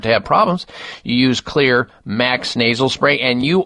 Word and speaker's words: Xlear: Well, that is Clear Max Nasal Spray. The Clear is spelled X-L-E-A-R.